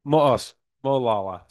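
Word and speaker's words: Molalla: Olala. Did I say it right? Yeah. Molala. Sorry. The town of Molala, the hamlet of Molala.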